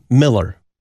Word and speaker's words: Mueller: He was my district president, a fellow pastor, and then eventually first vice president of the Missouri Synod, Herb Miller. Now, it sounds like M-I-L-L-E-R, but it was M-U-E-L-L-E-R. That's the proper pronunciation of that name.